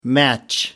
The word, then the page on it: match-up: British English